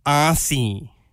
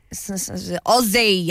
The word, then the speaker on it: Aussie: So she finds it really annoying that Americans say, arsey. – You're an arsey. Oh, that's so cool. – Why do they always have to, like, really strong the S in it? You're an arsey. – Aussie, yeah.